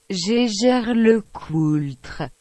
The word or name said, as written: Jaeger-LeCoultre